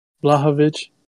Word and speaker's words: Blachowicz: Blachowicz.